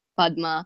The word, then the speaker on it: Padma: Just for both of them, it's the first syllable, Parvati, Padma.